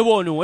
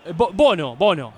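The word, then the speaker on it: Bounou: ¿Qué le digo, Bonu o Bono? – Bono. Ah, Bono. Yacine Bono. – Hoy me mandó el Budo una pronunciación, es Bono. – Si querés es un poco más francés, pero más conocido por nosotros como Bono.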